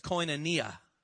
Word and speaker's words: koinonia: We would pronounce that as koinonia. Koinonia.